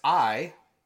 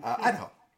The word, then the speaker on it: Idaho: And if you don't know how to pronounce that, that's I-duh-ho. Mm-hmm. Idaho.